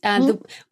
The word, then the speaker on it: Windhoek: And it is interesting because I'm going to ask you about the language now, because Windhoek, which I was pronouncing as it's spelt Windhoek, you know, because the V and the W.